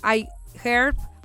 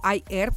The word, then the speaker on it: iHerb: Ajá, en Amazon, en este, en iHerb o iHerb, como quieran pronunciarlo, en Mercado Libre.